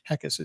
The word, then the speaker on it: Heckes: Heckes is spelled H-E-C-K-E-S. So. – Yep, like heck yes, without the Y.